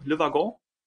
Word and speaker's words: Le Wagon: Ist das eigentlich französisch? Spricht man, ich habe das richtig ausgesprochen, Le Wagon? – Ja, ja, also besser als ähm, die meisten Interview Interviewers oder die meisten Bewerber, die ich habe auf jeden Fall.